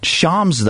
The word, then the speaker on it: Shams: Although his name is Shams, though. That's how you pronounce it.